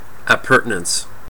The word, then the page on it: appurtenance: American English